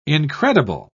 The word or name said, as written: incredible